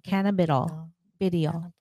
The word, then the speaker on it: cannabidiol: Cannabidiol.